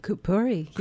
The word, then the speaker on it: Kapuri: Interna believes that beauty and wellness come through our connection with the, am I pronouncing this right? Kapuri? – Kapuri, yes.